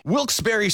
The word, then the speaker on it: Wilkes-Barre: Wilkes-Barre Scranton's 97.9X. the only way to pronounce Wilkes-Barre.